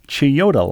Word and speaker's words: Chiyoda: Fast forward to now, and I have to take both the, I'm going to mispronounce this, the Odakyu line, O-D-A-K-Y-U line, and the Chiyoda line, C-H-I-Y-O-D-A, Chi like Chicago, Yoda like Yoda, to get to my school.